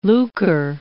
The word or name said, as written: lucre